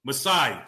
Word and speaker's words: Masai: Masai, jeez.